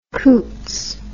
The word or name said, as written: coutts